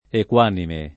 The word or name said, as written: equanime